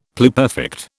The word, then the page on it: pluperfect: British English